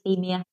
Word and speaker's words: euthymia: Euthymia.